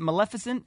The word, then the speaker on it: Maleficent: Maleficent? – Yeah, I think it's pretty close.